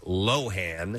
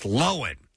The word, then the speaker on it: Lohan: At least, actually, the first time she was on Saturday Night Live, that's when I realized that it's not Lohan. – Yeah. – It's Lohan.